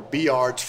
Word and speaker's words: Bulliard: B-U-I-L-L. – B-U-L-L-I-A-R-D. – B-U-L-L-I-A-R-D. And that's pronounced Beeyard. – Beeyard.